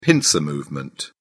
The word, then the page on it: pincer movement: British English